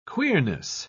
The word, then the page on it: queerness: British English